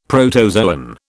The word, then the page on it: protozoan: British English